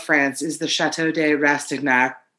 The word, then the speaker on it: Rastignac: R-A-S-T-I-G-N-A-C. Chateau de... – Mm-hmm. – Rastignac? – No, okay. You would see... You say the C. – Oh, okay. – You pronounce the C. – Do you say knack like a big ol' American? – Yes.